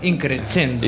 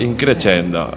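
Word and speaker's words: In crescendo: Los segundos, Dot, con su tema Amanecer Increscendo, que siempre lo digo mal. Y Aerios, Ni un golpe más. Y tenemos hoy a otro grupo. – Acento italiano, Increscendo. – Increscendo. Ya está Guille para corregirme, claro.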